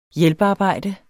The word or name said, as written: hjælpearbejde